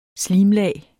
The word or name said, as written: slimlag